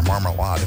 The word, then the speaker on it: Marmalade: Wants to hear Marmalade, or Marmalade if you want to pronounce it like the Ferners do.